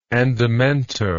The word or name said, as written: andamento